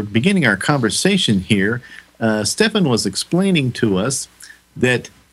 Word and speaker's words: Stephan: Uh, he corrected me. I was mispronouncing his name earlier. It's Stephan.